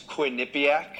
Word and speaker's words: Quinnipiac: And when you graduated from Michigan, you started out with Quinnipiac, right? Am I pronouncing that right? – It's Quinnipiac.